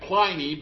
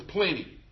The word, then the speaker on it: Pliny: You want to translate that, you want to pronounce that Pliny, but there's no E after the N, so it's Pliny.